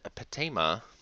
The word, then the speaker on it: Patema: and all of the vowels in patema are pronounced as they are in father.